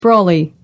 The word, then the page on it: brolly: American English